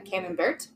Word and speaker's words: Camembert: brought us forth a cam camembert, uh, camembert. How do you say it? Camembert. Camembert. Thank you.